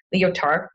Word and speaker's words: Lyotard: In 1979, Jean-François Lyotard, I butchered that, it's French, Lyotard, published a book called The Postmodern Condition.